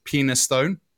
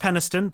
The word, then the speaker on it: Penistone: But there are a couple of names here for places in Yorkshire, and one of them is Penistone. – Pronounced Peniston, but yes, it is spelt Penistone.